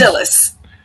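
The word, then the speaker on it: Dilys: Her novels have been nominated for many awards, including the Edgar 2RT Reviewers Choice Awards and the Agatha 2 Dilly Wins. Do I have that pronounced right? Dilly Wins? Dilly Wins? – Dillis. – Dillis Wins. Boy, they misspelled that. It's D-I-L-Y-S.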